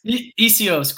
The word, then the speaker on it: Essihos: Essihos. Is that right, Essihos? – Essihos, close. Close.